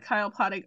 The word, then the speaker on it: Kyle Poddig: You can find me on Instagram at Kyle Poddig Arts. That's KYLEPODDIGARTS. Blue Sky at Kyle Poddig. And that's just Kyle Poddig, my name.